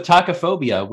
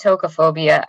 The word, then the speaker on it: tocophobia: I have been pronouncing it tocophobia. I don't know if maybe some people do pronounce it tacophobia, but it doesn't really matter.